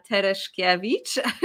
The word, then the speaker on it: Tereszkiewicz: My name is Amelia Leila Tereszkiewicz.